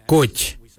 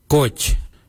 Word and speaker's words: goodj: Kylie is spelled G-I-L-Y-I, Kylie. For the traditional stone axe, we say Gotch, Gotch. Gotch is spelled G-O-O-D-J, Gotch.